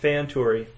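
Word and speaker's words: Fëanturi: When you see that umlaut over the E, that means... Well, what that... So two things. That means... So the E sounds like a fe, like A, right? – Right. – But the umlaut over it means that you're supposed to ensure you pronounce it, right? – Right. – Otherwise, you might have just said Feanturi. – Yes. – But because the umlaut's over the E, you say Feanturi.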